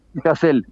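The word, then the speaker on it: Hasél: porque he visto que se dice Hassel y otros dicen Hassel. – Cassel, con la acentuada en la E.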